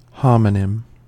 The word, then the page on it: homonym: American English